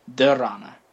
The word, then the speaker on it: Dharana: Let me just make sure I'm pronouncing it correctly. After Pratyahara comes Dharana.